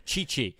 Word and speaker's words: Tchê Tchê: But I, I also really liked, and I had to ask Pedro for the pronunciation of this Tchê Tchê. Because I was like, is it? Chi-? And he was like, yeah, it's like Tchê Tchê, but it's with a with a better accent, of course, than I've got.